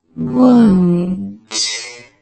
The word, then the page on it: won't: British English; slow